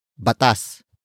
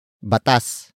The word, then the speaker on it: batas: The second word is batas, batas, batas. Batas is spelled as B-A-T.